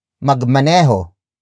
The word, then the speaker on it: magmaneho: For example, magmaneho. Magmaneho. Magmaneho. Magmaneho is spelled as M-A-G-M-A-N-E-H-O.